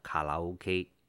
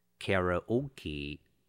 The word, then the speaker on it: karaoke: 一个人到日本一定要体验一晚的卡拉 OK。 Experience 动词就是体验的意思， Karaoke 卡拉 OK，请小心发音，在英文里我们念成 karaoke，可不要念成了卡拉 OK。